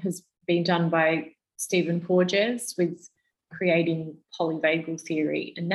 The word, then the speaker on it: Porges: Porges, so P-O-R-G-E-S.